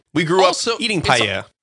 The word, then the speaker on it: paella: That's the correct way to say it, by the way. – It's not paella. – Paella? No, paella. – I like paella. – Yeah, it's like three different vowel sounds all in the same time.